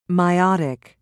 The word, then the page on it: meiotic: British English